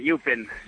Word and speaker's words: Eupen: ¿Pronunciación exacta del equipo? – CAS Eupen, Eupen. – Eupen. – Hablamos alemán ahí.